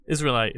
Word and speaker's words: Israelite: Israelite, like Israel, like the like, that's country.